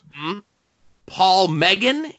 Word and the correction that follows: McGann: is going to be there. Is that how you pronounce that? – McGann, the Eighth Doctor.